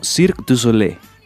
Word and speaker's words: Cirque du Soleil: Que por cierto, ¿cómo es la pronunciación en francés? Yo de francés cero. Eh, Recuérdenoslo. bueno, espero estar pronunciándolo bien, no, no quedar aquí en vergüenza, pero sería algo así como Cirque du Soleil. Perfecto.